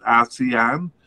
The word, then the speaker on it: ASEAN: So we said they were part of the AFF region, or the I don't know how to pronounce it, ASEAN. It's A-S-E-A-N, so it sounds like Asian.